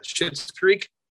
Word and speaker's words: Schitt's Creek: The popular one that I feel like a lot of people binge watched, the comedy out of Canada, Schitt's Creek. Is that right? Did I say that right? Yeah.